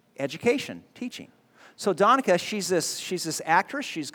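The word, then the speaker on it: Danica McKellar: I think it's Donica. That's how you say her name.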